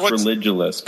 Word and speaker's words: Religulous: It's pronounced religulous because it's a conflation of religion and ridiculous, so religulous.